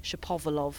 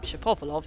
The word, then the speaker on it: Shapovalov: So we had the whole Shapovalov things that it became Shapovalov after about a few months, and people checked with him and he said, Shapovalov, who's that? Shapovalov. We'd all been saying Shapovalov for a while, and we thought, okay, right, you've got to try and ingrain that in the brain.